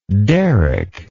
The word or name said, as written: Derek